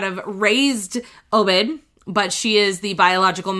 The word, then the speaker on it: Obed: I think it's Obed. I think it's how they pronounce it, O-B-E-D.